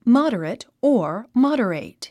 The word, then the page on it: moderate: mάd ə rət; モォデレェイト